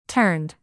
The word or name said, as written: turned